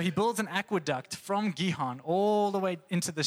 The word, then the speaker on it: Gihon: Hezekiah looked at the spring of, I think it's Gihon, that's how you say it.